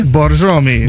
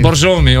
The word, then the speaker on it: Borjomi: It's called Borjomi. – Borjomi. – Borjomi.